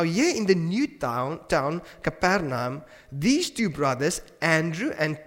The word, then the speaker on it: Capernaum: Is that how you pronounce it? Capernaum, yes.